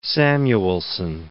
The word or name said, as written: Samuelsson